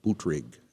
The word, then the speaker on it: Buttigieg: You know, four or five of them, I may be mispronouncing his name, Buttigieg.